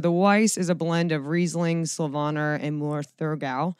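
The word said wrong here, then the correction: Müller-Thurgau: Müller Thurgau. – Okay, so we're not great at I'm still not great at pronouncing Müller Thurgau.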